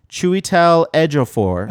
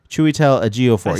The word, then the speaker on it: Chiwetel Ejiofor: We've got, and I was just double-checking that I could say it right, Chiwetel Ejiofor. – Okay, I've seen him in other things. – Ejiofor, sorry. Chiwetel Ejiofor.